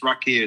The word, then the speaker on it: Raqia: And called Rakia, who are based here in LA. Amazing musicians. Rakia is spelled R-A-Q-I-A.